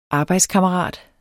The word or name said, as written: arbejdskammerat